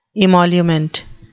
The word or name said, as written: emolument